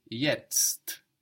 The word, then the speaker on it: jetzt: So it's like the English word yet with an ST, an extra ST. So if this is complicated to pronounce, you can think of the English word yet, and then after you think of that, add on the ST, jetzt.